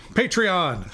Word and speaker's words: Patreon: It's a Patriot account. – That's right. No, not Patriot. Patreon.